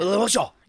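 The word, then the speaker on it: Lemarchand: Is that how you say it, Lamarchian? Lamarchian? – Yeah, but you say it with a really French accent. – Lamarchian. – Yeah, there you go.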